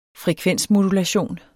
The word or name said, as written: frekvensmodulation